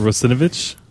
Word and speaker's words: Russinovich: Uh, in other in non uh, technical news, uh, one of my favorite authors, uh, Mark Rusunovich. That almost came out completely wrong. How do you say that, Russinovich? – Yes, thank you.